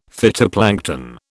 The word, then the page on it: phytoplankton: British English